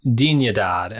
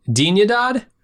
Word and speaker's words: Dignidad: You can't be saying that right. – Dignidad? – No, man. That's a hard G. – Dig. – In Spanish. – Dignidad.